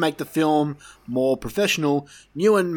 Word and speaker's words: Nguyen: Nguyen. – Okay, there you go. The G is silent, apparently.